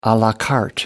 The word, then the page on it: a la carte: American English